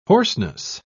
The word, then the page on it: hoarseness: British English